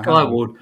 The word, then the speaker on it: Skywald: Skyward. Okay. I'll spell it for you. It's SKYWALD. And you should Google them because I'm sure it'll come up.